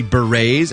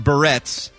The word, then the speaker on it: berets: Yeah, you know why also you have to pronounce it that way? Because that's the way it's pronounced. – Everywhere. – Everywhere. I know you'd like to call it berets, but unfortunately we have to try to speak correctly from time to time.